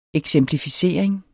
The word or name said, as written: eksemplificering